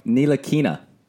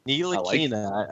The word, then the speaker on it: Ntilikina: Ntilikina. – Ntilikina.